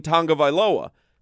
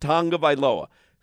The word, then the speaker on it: Tagovailoa: Somebody calls in and Solana answers, and the guy goes, you guys are constantly mispronouncing Tua Tagovailoa's name or whatever, you know, because there is no N in Tagovailoa. But Tua himself has done interviews. That's how it's, that's the Hawaiian pronunciation. It is pronounced Tagovailoa.